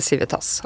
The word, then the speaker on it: Civita: heter Civitas. Og da måtte de kutte ut S-en. – Og da betyr det kanskje nesten ingenting, men eh, han mente likevel vi burde si det uttrykk, eller uttale det Civita.